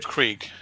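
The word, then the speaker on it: Alice Krige: Like, the lady who's playing the Borg Queen, we warned you about a spoiler, it isn't Alice Kurge? Kridge? Alice Kridge? – Krieg. – Krieg, thank you.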